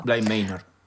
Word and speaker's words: Bly Manor: Bly Manor.